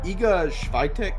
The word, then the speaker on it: Iga Świątek: Since I've gotten into the sport, whenever I first started watching it like a lot, Iga Świątek, that's how you say her name.